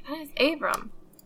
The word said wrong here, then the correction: Ibram: Doing it again. Ibram? – Ibram.